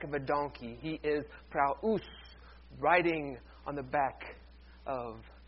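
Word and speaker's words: praus (πραΰς): The word we translate as meek is praus. Two syllables. Say it with me. Praus.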